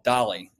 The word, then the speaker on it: DALL-E: there's another tool called DALL-E, and it's a play on Salvador Dali, right? But it's spelled DALL with a E, you know, electronic. It's kind of like WALL-E, I guess, the merger of those two things.